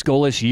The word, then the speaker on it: Golesh: G-O-L-E-S-H. – Coach. – Fish. – Coach. Alex Goalish, USF Bulls.